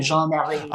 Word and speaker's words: Jean Marie: Nor am I, but my name is actually Jean Marie, which is a French name, Jean Marie.